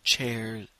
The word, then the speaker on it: chairs: Trois, c'est three, et chaise, c'est chairs. N'oubliez pas d'ajouter cet S qui se prononce comme Z ou Z.